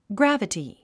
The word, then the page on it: gravity: ɡrǽvəti; グラバティ